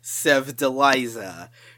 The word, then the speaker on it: Sevdaliza: This one is pronounced Sevdaliza, coming in with track number 23.